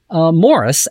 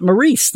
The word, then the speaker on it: Maurice: I just did, aside from my column, I just did a huge interview today with Rick Berman about Maury Hurley, whose first name, number one, is actually pronounced Morris and not Maurice, the way we've all been saying it for all these years.